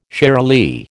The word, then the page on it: shiralee: American English